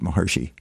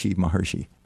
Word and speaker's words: Maharishi: So anyway, so what Maharshi said, Is it Maharshi or Maharishi? – He pronounced it Maharshi. Maharishi.